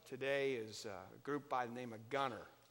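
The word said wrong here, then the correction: Gungor: Am I saying that right, Matt? Gunger.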